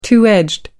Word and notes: two edged: American English

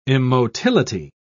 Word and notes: immotility: British English

